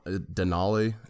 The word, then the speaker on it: Denali: So, have you guys heard of Mount Denali? Am I saying that right? – Yeah, it used to be Mount McKinley, but after that blew up or whatever, now it's Mount Denali.